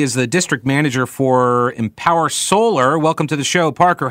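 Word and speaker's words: EMPWR Solar: Just Yeah, we dropped some vowels there. E-M-P-W-R. So Empower Solar. You got it right.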